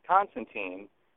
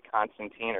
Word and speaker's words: Constantine: And at one point, John is giving his name to someone for a form, and he says, Constantine, spelled T-I-N-E, rhymes with fine.